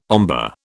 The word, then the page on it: ombre: British English